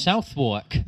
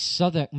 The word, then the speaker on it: Southwark: Southwark. – Southwark. – We're calling it Southwark. It is my... – How should you pronounce it? – It's Southwark, mate.